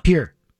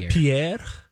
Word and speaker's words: Pierre: Pier. – Whoa, nice. Oh. – With the correct pronunciation, too. – Pier. – So it's not Pierre? – I believe they call it Pier.